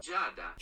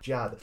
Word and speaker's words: Giada: I want to hear it pronounced Italianically. That's a word. – I fully believe you. – Yep. – Giada. – Giada. That's kind of what I felt it was going to be, like the blending into a single syllable.